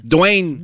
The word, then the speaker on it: Dwayne: Dwayne.